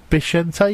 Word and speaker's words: Vicente: I keep saying Vicente, but it's supposed to be supposedly Vicente. You pronounce it a B in Spanish.